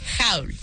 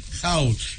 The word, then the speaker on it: haul: Haul. – Haul. Y se escribe H-A-U-L.